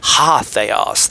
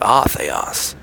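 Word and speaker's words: Ha theos (ὁ θεός): It's like in Greek, when you see the word the, and it's an A, or an alpha, and you're like, ah, no, it's not ah, it's ha. See, there's a little apostrophe, and that means pronounce it with an H. Ha theos, the world. Not ah theos.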